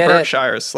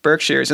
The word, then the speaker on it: Berkshires: Yeah, I was calling it Berkshires, like Lord of the Rings, but no. Berkshires. And then I spent the whole time trying to remember what song references the Berkshires from Stockbridge to Boston.